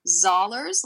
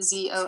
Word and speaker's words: Zollars: On Instagram, which is the main one I'm pretty much using, it is just my name, which is spelled kind of different than you might think. It's Jaime Zollars, like dollars with a Z, Zollars, Art, A-R-T.